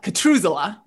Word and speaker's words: Catruzela: Um, and one of the things that, um, if you listen to the AMR trains episode with Kara, uh, uh, I say her last name rhymes with Methuselah, Catruzela, Kara Um, the other day, we were talking about habits, right?